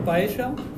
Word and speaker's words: paella: Después lo otro que pronuncian bien es paella. – Ah, paella. Paella. No. – Paella. Paella. Paella. Paella. Paella. – Paella. Si es que no saben cómo se pronuncia doble L.